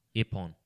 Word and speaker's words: ippon: Ippon.